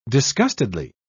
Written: disgustedly

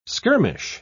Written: skirmish

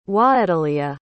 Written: hwaeteleah

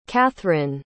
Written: catharine

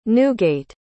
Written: Newgate

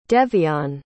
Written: devion

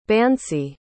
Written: bansi

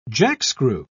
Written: jackscrew

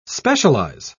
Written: specialize

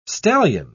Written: stallion

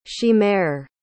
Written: chimere

